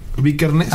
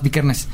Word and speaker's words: Vikernes: Bar, Bar Vickers. Ajá. Vikernes. lo vas, Vikernes.